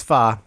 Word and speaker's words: pho: I mean, technically it's pho, but I like to say pho. It makes me feel better. – P-H-O.